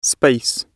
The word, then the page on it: space: British English